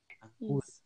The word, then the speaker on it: Acutis: O nome dele, espero que, espero que se eu estiver falando errado, as meninas me corrijam, é Carlo agur, agur, agurtes, é isso? acutes, isso. Acui, acutes. – Acutis.